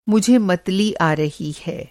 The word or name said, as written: मतली